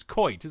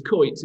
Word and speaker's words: Coyte: I never pronounce his name right, which is an appalling admission from somebody whose name gets pronounced wrongly all the time. It's Coit, isn't it? – It's Coit, yeah. C-O-Y-T-E.